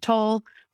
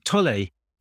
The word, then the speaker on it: Tolle: I call him Tolle, but I think that's because I know he's German and that's a German pronunciation. But I think a lot of people call him Toll, you know, because that's more of an American pronunciation.